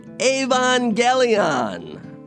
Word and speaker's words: Evangelion: So she had been posting for a while about Evangelion. And that is the proper way to pronounce it.